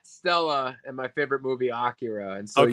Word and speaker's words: Akira: Do you pronounce it Stelkira? Is that how you pronounce it? – Yeah, Akira. – Akira, okay. Yeah, Stelkira, like Akira.